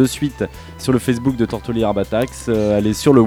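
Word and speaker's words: Tortolì: Tortoli, je prononce jamais le R.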